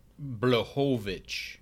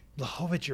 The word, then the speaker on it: Błachowicz: It's called Błachowicz. – Błachowicz, you're right. I always mispronounce it.